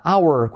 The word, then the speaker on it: hour: Number 59, hora, hour. Curiosidad, esta palabra, la H no se pronuncia. Hour.